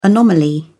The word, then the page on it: anomaly: British English